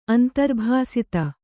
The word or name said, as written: ಅಂತರ್ಭಾಸಿತ